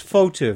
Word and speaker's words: Fauteux: It's hard to pronounce. – F-A-U-T-E-U-X. Fautu. Very easy.